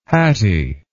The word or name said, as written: Hatti